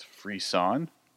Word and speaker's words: frisson: This one I almost jumped over when I was making my list, and I had to look up the pronunciation of it. It's frisson, which means like a thrill that you get.